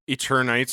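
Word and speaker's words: Eternights: Following Roller Dome, we got, I think it was Eternites is how they pronounce it. – It's supposed to be like a play. I think they were trying to make a play on Eternal. – That's what I thought so too. – So but Eternites. So I think you said it right.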